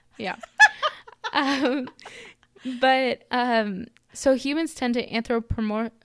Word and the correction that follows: anthropomorphize: Okay, anthropomorphize. – Uh-huh. I think I uh-huh. missed a syllable.